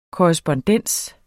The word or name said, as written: korrespondens